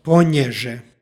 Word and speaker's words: ponježe: stihu pročitali smo veznik ponježe sa nj, dakle ponježe.